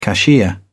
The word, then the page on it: cashier: British English